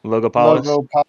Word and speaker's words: Logopolis: A famous line of the Masters from the original Doctor Who series, Logopolis. Did I even say that right? Logopolis? – Yeah, that sounds about right.